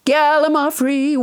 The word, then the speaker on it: Gallimaufry: Gallimaufry, which I pronounced wrong until nobody James Hyder corrected me.